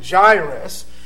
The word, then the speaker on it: Jairus: I like to pronounce it Jairus.